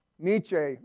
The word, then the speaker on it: Nietzsche: But I'm reading a book, and the author is quoting a guy named, and I'll probably butcher the name, Friedrich Nietzsche. What was it? Nietzsche.